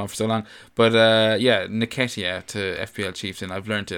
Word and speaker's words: Nketiah: I think it's like Nketiah. No, you even had it a while ago. It's like Nketiah.